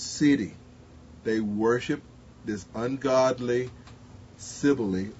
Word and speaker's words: Cybele: I learned the other day it's not Sybil, that Eli is pronounced Sibylle, who was identified with Greek Artemis.